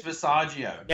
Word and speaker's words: Visaggio: How do you say the name? Visaggio? – Mags Visaggio.